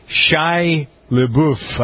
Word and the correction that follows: Shia LaBeouf: Shia LaBeouf.